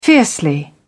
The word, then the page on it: fiercely: British English